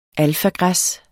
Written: alfagræs

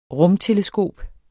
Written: rumteleskop

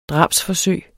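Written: DRABSFORSØG

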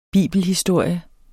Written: bibelhistorie

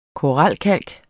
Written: koralkalk

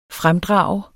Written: fremdrage

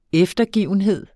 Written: eftergivenhed

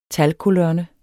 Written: talkolonne

